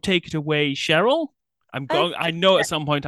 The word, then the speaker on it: Cheryl: Are you pronouncing it Cheryl or Cheryl? – Cheryl. – Cheryl. Okay, great.